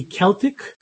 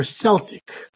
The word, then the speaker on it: Celtic: It can either be pronounced with a K sound, or it can be pronounced with a S sound. So it can be Celtic or Celtic.